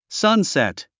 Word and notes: sunset: sˈʌnsèt; サンセット

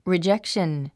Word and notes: rejection: ridʒékʃ ə n; リィジェクション

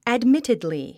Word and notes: admittedly: ædmítidli; アドミティドリィ

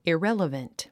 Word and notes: irrelevant: iréləvənt; イレラバント

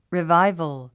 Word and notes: revival: riváivəl; リィバイボゥ

